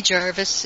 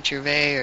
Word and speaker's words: Gervais: And Ricky Jarvis is noted. I know people say his name differently, Gervais or Jarvis.